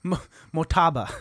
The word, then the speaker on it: Motaba: You're putting emphasis on the wrong vowel. Motaba. – Motaba. – You know what? No, it sounds like you're asking for more taba.